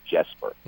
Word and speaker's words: Jesper: but he has decided that he is Jesper.